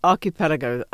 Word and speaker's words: Archipelago: Archipelago. Do I say it right? – Archipelago. There's different ways of saying it.